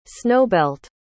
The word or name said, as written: snowbelt